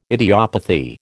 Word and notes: idiopathy: American English

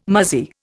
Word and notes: muzzy: Indian

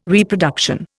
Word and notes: Reproduction: Indian

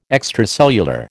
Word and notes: extracellular: American English

